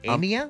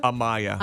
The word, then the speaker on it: Amaya: How would you say it? A-M-A-Y-A. Amia? – Amaya.